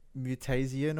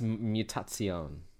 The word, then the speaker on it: Mutazione: Is it Mutazian or whatever? – Look at that. Yes, it is. – I think it's pronounced Mutazione. A little bit of an Italian flair to it.